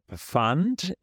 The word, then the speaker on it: Pfand: There's the Pfand.